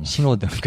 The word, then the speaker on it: 신호등: 신호등. 그치. 거기 히읗이 발음이 안 된다고. 신호등.